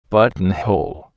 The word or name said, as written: buttonhole